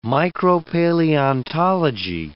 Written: micropaleontology

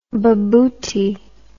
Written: bobotie

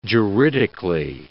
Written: juridically

